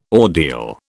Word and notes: ordeal: British English

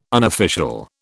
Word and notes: unofficial: British English

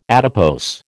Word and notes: adipose: American English

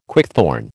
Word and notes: quickthorn: American English

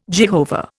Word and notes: Jehovah: Indian

